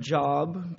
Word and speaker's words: Job: If you're new to the Bible, it's spelled like the word job, but we pronounce it Job.